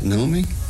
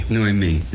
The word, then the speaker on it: Noemí: Luego Elimelech y ¿cómo se pronuncia? Naomi. – Noemí.